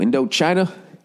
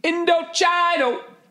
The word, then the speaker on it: Indochino: Indochina? Indochino!